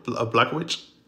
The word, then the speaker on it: Blachowicz: This is Israel Adesanya versus John Blachowicz. Blachowicz. – Uh, okay. – I, am not even sure that's how you say his name 100, percent but I think you have it right. It's Polish.